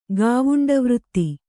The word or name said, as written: ಗಾವುಂಡವೃತ್ತಿ